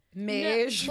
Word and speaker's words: mage: Mage. – No. Like rhymes with beige.